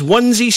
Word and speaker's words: Onez: That's right, sorry, I did say Onesie. It is spelled Onez. But it is Onesie Station, which is a beautiful, beautiful Orbis.